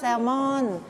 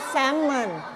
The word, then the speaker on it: Salmon: ภาษาไทยเราจะเรียกว่า Salmon แต่ภาษาอังกฤษอ่านว่า Salmon Salmon ไม่ออกเสียง L นะ